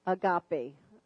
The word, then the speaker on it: agape: And love, the Greek word for love is agape. And that's spelled A-G-A-P-E. I don't see too many pens moving. Agape. A-G-A-P-E. So that's God's love.